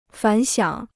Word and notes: 反响: fǎn xiǎng

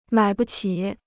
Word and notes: 买不起: mǎi bu qǐ

